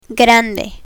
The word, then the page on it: grande: spanish